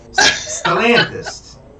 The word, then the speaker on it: Stellantis: Stellantis. Yeah. – Okay. – It's like Stella with NTIS at the end of it.